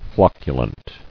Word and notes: Flocculence: floc·cu·lent

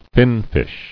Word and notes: Finfish: fin·fish

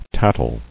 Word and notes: Tattling: tat·tle

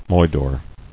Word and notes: Moidore: moi·dore

